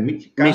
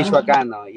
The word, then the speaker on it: Michoacano: But we are super excited today to have Miguel El Michoacano, right? That's how you say it, Michoacano. – Michoacano, yeah.